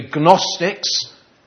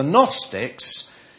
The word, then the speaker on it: Gnostics: They're known as the Gnostics. You don't really pronounce the G, but I did, so you can recognise the spelling there. So it's like Gnu.